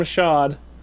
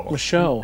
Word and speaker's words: Michaud: One more again from Sean Michaud. – Michaud. – Sorry, yes. The D is silent.